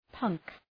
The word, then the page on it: punk: pʌŋk